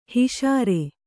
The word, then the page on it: ಹಿಷಾರೆ: kannada; hiṣāre